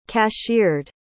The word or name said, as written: cashiered